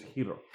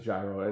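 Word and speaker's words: Hiro (γύρος): Hiro. The proper Greek pronunciation is Hiro. – Hiro. – But we're in the U.S. and it's gyro, and you get over it, you know.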